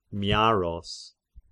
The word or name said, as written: Miaros